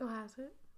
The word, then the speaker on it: Cohasset: So, in the basement of Anna and Brian's house, in Cohasset. I just like to say Cohasset. – Cohasset.